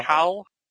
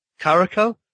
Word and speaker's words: Caracal: Is it Caracal? – Caracal. – Caracal. – Yeah.